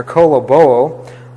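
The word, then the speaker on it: koloboo (κολοβόω): koloboo. K-O-L-O-B-O-O, and the last O is a long O, the omega there.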